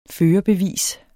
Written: førerbevis

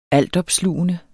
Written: altopslugende

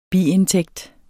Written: NETTO

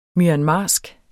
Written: myanmarsk

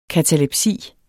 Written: katalepsi

